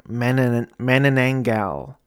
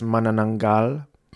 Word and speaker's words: Manananggal: And I showed it to my wife because my wife was born and raised in the Philippines, and Rebecca kept saying Manananggal, and I guess the Filipino pronunciation is Manananggal.